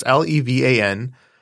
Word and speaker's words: Levan: Unfriended is a 2014 screen life supernatural horror film directed by Levin Gabriadze. You know me and my pronunciations. It's Levan. That's the first name.